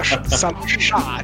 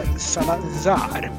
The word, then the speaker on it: Salazar: Jag fick backning på hur jag uttalade Portugals diktators namn. Vi ska typ inte vara Salazar, utan vi ska Salazar.